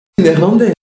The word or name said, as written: Néerlandais